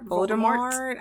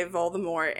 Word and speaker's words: Voldemort: That he is Voldemort. I don't know. Mm-hmm. I don't fuck with Harry Potter. – English people say Voldemort, and then I believe the French pronunciation would be Voldemort.